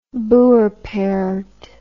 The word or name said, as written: boerperd